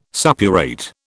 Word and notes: suppurate: British English

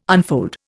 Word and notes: unfold: Indian

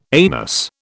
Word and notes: anus: British English

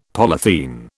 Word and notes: polythene: British English

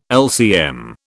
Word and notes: LCM: British English

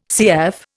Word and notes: CF: Indian

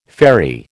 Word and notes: faerie: American English

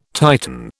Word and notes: Titan: British English

